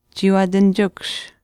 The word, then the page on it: chiiwaatinichihkuhsh: Chisasibi